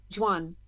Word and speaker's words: Jhon: So, J H O N. How do you pronounce that? Is it still John? Juan. Juan. Juan Duran.